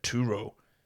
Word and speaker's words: Turo: That's T-U-R-O. I guess in America, how would you say it in America? Turo. I said Turo. Yeah, that's the same.